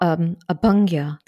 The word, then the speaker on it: Abhyanga: So in terms of Ayurvedic rituals, I was going to ask you about Abhyanga. Have I pronounced that correctly? – Yeah, Abhyanga masa.